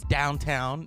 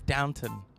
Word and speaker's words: Downton: My friend says it's downtown. No, it's Downton.